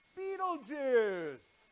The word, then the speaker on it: Betelgeuse: And then my favorite is Betelgeuse. Betelgeuse. Now some people pronounce that different, but because I'm the speaker, that's how I'm going to pronounce it.